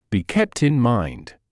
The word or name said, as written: be kept in mind